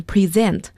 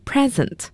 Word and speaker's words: present: Next one is present，大家要注意一下这个单词的发音，它做动词，所以要读成 present，如果做名词的话读成 present，我们叫礼物，要注意动词 present，名词 present。